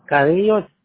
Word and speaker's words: Carrillo: Hector Carrillo Carranza LLC, but I also stop in my LLC. My wife put Hector Carrillo, Carranza, cleaning service. – Gotcha. How do you spell that? Hector Carrillo? K-A-R-I-Y-O? – Carrillo, C-A-R-R-I-L-L-O, yes. – C-A-R-R-I-L-L-O-S? Hello? – Yes, I'm here. C-A-R-R-I-L-L-O.